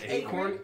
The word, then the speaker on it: Akron: I'm pretty sure it's Acorn. – Yeah, it's pronounced Acorn.